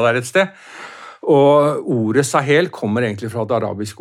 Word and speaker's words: Sahel: Du har en årrekke nå arbeidet med spørsmål knyttet til krig, konflikt og samfunn i Nord-Afrika og Sahel. Er det riktig uttale? – Ja.